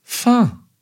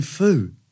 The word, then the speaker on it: pho: The word like a foe. No, you say, I'm going for pho.